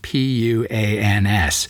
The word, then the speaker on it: Puans: P U A N S, pronounced something like point in French. I'm going to speak it sort of an English way.